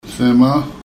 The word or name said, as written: semaa